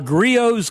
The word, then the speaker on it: Griot's: Yes, Griot's Garage, G-R-I-O-T-S, that's how it's spelled, the T is silent.